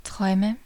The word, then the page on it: Träume: /ˈtʀɔɪ̯mə/